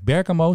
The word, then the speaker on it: Bergamo: En die vloog naar, even kijken, Bergamo, zeg ik dat goed, in Italië.